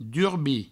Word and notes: durbir: Provençal; durbi

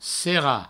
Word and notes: serrar: serra

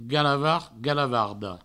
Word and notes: galavard, galavarda: galavard, galavardo

